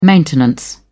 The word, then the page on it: maintenance: British English